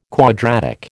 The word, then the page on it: quadratic: American English